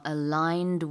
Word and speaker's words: aligned: How do you spell aligned? – A-L-I-G-N-E-D, aligned. – Exactly. There's a G in there. Yeah.